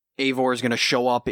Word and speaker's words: Eivor: It's pronounced Eivor.